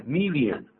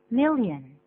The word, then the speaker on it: million: 对，我们可是常常听了我们的老中啊，念这个字念 million。 – million 比较奇怪啊，注意发音，念个 million。 million。 – million。那么实际上呢是 mil，舌尖要抵上去哦，再放一个眼睛，就没有眼睛这样念吧。